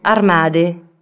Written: armari

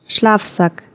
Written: śpiwór

